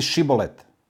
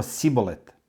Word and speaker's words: šibolet: Kada bi on odgovorio nisam, oni bi mu rekli, ajde reci šibolet, a on bi rekao sibolet, jer nije umeo ispravno da izgovori tu reč.